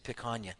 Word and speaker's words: picanha: Is that the name of that? That's how you say it, picanha.